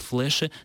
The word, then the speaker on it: флеши: что современники произносят багратионовые флеши с твердым «л».